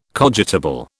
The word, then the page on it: cogitable: British English